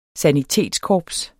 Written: sanitetskorps

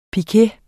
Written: piquet

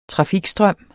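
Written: trafikstrøm

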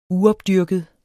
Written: uopdyrket

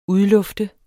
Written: udlufte

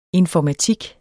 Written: informatik

